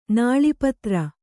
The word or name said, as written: ನಾಳೀಪತ್ರ